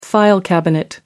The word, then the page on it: file cabinet: American English